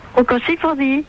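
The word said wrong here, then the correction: Rocco Siffredi: Eh non, c'est Rocco-Sifredi. – Freddy, oh ben, j'ai mal prononcé.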